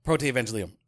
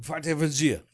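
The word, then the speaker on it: Protoevangelium: No, Proto-Evangelium. Okay. – No, I'm going to say it wrong. Now I'm going to... Proto-Evangelium. – Well, you can say it either way. Hard G, soft G.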